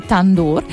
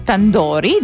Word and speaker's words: tandoor: E davvero di norma si cuoce questo pane nei forni detti tandoor o tandori, dipende un po' anche come lo vogliamo pronunciare,